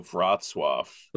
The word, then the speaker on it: Wrocław: Oh, Wrocław.